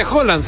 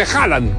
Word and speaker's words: Haaland: No se dice Holland, se dice Haaland.